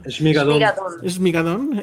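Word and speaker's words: Schmigadoon: Jimena Lipman dice, está muy padre, híjole, ¿cómo se pronuncia? – ¿Shmigadón? – Shmigadón. En español no es chingadón.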